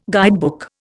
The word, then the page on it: guidebook: Indian